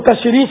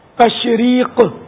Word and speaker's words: تشریق: تشریق یہ اس کی تلفظ بھی نہیں سمجھ میں آئے گا سب کی، وہ تشریح سمجھتے ہوئے تشریح. تو تشریق آخر میں دو نقطے والا قاف ہے، تشریق ایام تشریق.